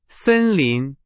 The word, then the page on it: 森林: Chinese